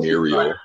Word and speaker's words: Muriel: You know, that just made me think of that episode with the foot and like, what was Miro doing? She wanted, I'm saying that so wrong. I can't form her name with my mouth. – Muriel? – I feel like I'm saying that wrong. – Muriel? – Muriel. Okay. Anyway, the one with the foot fun guy, like, she made him put his foot in, like, a bucket of crabs.